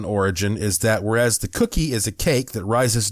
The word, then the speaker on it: koekje: And it says also the Dutch language from around 1703 had adopted the word cookie, which is spelled K-O-E-K-J-E, cookie, which means little cake, to have a similar meaning for a similar hard baked product.